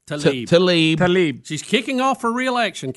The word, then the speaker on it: Tlaib: Talab? – Rashida Tlaib. – Tlaib. Is there some silent letters here? – Tlaib.